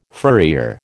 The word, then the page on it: furrier: American English